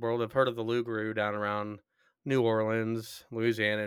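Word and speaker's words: loup-garou: Loop Garoop. But it's called the Lugaroo, werewolf. – Um, You didn't pronounce a single one of those P's.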